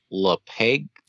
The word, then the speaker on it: LaPaglia: And then next, we have Anthony LaPaglia. I think that's how you say his name. – Yeah. So some people may know him from other roles, but he plays Mechanic 2.